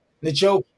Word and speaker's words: Njoku: I can't say it right. Njoku, thank you. Right. I had him last year. Really hit and miss. Like, there's so many mouths to feed. – Oh, Njoku.